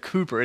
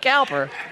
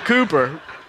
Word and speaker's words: Cowper: I'm glad we said Cooper. It's spelled Cowper, right? And I'm glad that you said it before me so I didn't look like, this guy said Cowper. It's William Cooper.